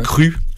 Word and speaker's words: Cru: Et donc c'est pour ça, donc on pourrait prononcer Jean Norton, mais ouais. Cru, par contre, à la française.